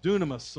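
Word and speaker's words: dunamis (δύναμις): It's a dunamis, and we see it as we pronounce it. If we were pronounced in the English, it would almost pronounce dynamis.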